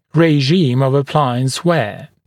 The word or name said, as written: regime of appliance wear